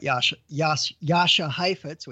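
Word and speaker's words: Jascha Heifetz: Talking about Yasha Heifetz, which is how you pronounce it, Colin.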